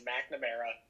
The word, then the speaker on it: McNamara: Yeah, just Mike Mack is usually, it's McNamara, but Mack is perfect.